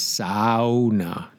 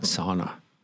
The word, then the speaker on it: sauna: Well, the Finnish, who invented the sauna, are famous for the sauna, they pronounce every letter. So it's S A U, S sauna, not sauna. Sauna. Sounds like you're sawing long, sauna.